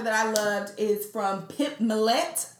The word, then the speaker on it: Pip Millett: Pip Millett.